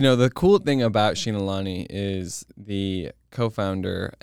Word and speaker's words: Xinalani: Xinalani, which is spelled X-I-N-A-L? – A-N-I? Xinalani.